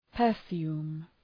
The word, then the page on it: perfume: pər’fju:m